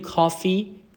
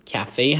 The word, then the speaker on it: کافه: رستورانت که همون رستوران، کافه، کافه، اینو نگین کافی، کافه هست.